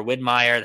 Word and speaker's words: Widmeier: Um, guys, you can follow Mitch um, M underscore Widmeier. That's W I D M E I E R on Twitter. – Nailed um, the pronunciation too, man. That's a difficult one for some people.